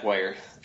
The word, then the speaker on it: Wire: but yeah, Wire. See it again, just like Meyer with a W.